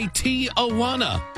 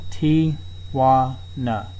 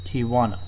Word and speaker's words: Tijuana: You say Tijuana. You put an extra sound before the wana. It is a three-syllable word, which you are pronouncing as four syllables.